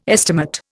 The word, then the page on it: estimate: Indian